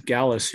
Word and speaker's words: Galas: It's uh, Sky High and Fly Jump Camps um, on Instagram and on YouTube channel and Paul Galas here.